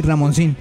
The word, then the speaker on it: Ramoncín: Sí, Ramoncín, sí. Con acento en la I.